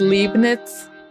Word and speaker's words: Leibniz: And it's pronounced Leibniz.